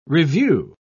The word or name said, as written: revue